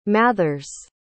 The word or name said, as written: mathers